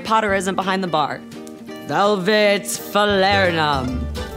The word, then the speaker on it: falernum: And the next one is falernum.